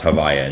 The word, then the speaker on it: Havaya: that's called Havaya. It's often pronounced Havaya in shorthand in Chassidus. They refer to that name. It's the switching of the letters so you're not pronouncing it properly.